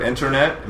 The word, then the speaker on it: internet: we don't pronounce the T. So anytime you have the NT in American English with the stress on the first part, mm-hmm. on the previous syllable, it becomes internet becomes internet.